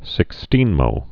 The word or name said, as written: sixteenmo's